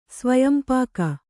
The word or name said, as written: ಸ್ವಯಂಪಾಕ